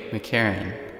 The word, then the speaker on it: McCarron: Charlie McCarron. – McCarron, could you spell that for me? – M-C-C-A-R-R-O-N.